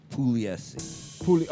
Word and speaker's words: Pugliese: Actually, in Italian, though, the G is silent, so it would be Pugliese.